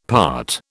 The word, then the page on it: part: British English